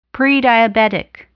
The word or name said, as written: prediabetic